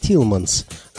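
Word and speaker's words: Thielemans: которого весь мир знает под именем Тутс Телеманс. На самом деле, его полное имя – Жан-Батист Фредерик Исидор Телеманс. В Америке его фамилию произносят как Тилманс, а Тутс – это прозвище, которое молодому музыканту дали приятели в послевоенной Бельгии, чтобы его имя звучало как-то более джазово.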